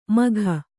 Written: ಮಘ

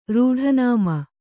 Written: ರೂಢನಾಮ

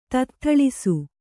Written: ತತ್ಥಳಿಸು

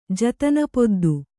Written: ಜತನಪೊದ್ದು

